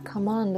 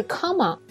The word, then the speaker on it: come on: 如果你去背的话，可能好久也背不完，因为我们不光要背 come on 是吧？我们还要背好多其他的意思，所以大家可以用一下我说的这个方法。那么在讲具体的例句之前，要先说一下 come on 的发音。嗯，它的重音在后边，不是 come on。如果你说 come on 就好奇怪哦。